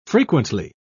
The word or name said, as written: frequently